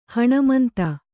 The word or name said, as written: ಹಣಮಂತ